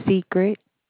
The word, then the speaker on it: Secret: Secret. – Secret? – Yes, ma'am. – How do you spell that? – S-E-C-R-E-T. – Oh, okay. It's like it sounds.